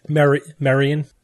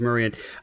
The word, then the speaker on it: Merian: For this week's Forgotten Superheroes of Science, I'm covering Maria Sibylla Marion, M-E-R-I-A-N. How would you pronounce that? – Marion. – I'm leaning towards Marion.